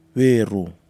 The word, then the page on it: nuirou: Nafe